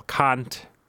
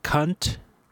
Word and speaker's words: Kant: Like, teenage boys, like, okay, so we had, this has nothing to do with that word, but we had a Hungarian monk who pronounced Immanuel Kant like Immanuel Cunt.